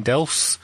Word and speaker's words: Delfs: So let's do a quick cash for question from Irene Delfs. – Rhymes with else.